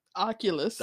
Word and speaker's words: Oculus: And the dude, you know, that was trying out the Oculus. How you say it? – Oculus.